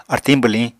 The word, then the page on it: artiñ bwliñ: Apatani